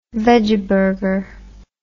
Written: vegeburger

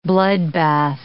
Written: bloodbath

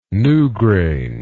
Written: newgrange

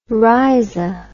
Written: rhiza

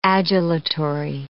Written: adulatory

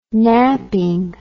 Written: naping

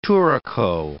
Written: turaco

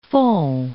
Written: fonds

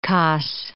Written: Cos

